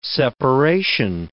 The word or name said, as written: separation